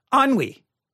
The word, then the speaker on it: ennui: If I looked at the word, I would probably not pronounce it ennui, but here is how it is spelled. E-N-N-U-I. Ennui. E-N-N-U-I.